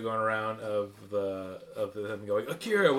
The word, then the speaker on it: Akira: I watched Akira. Is that how you say it? – Yeah. – No. Akira.